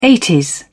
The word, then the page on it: eighties: British English